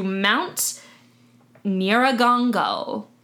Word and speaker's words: Nyiragongo: But it's located very closely to Mount Nyiragongo.